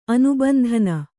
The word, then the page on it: ಅನುಬಂಧನ: anubandhana